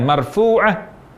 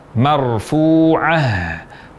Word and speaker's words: marfu'ah: Sehingga mengucapkan kata marfu'ah dengan marfu'ah, huruf H tidak jelas, ini tidak tepat. Yang benar, marfu'ah, marfu'ah.